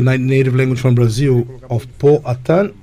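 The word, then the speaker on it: Poatan: The nickname is, am I saying it right, Potan? – Potan.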